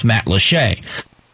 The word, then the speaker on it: Matlacha: how to pronounce Matlashay. Because it's spelled Matlacha, which is how people normally, Boquilia is another one.